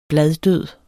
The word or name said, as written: bladdød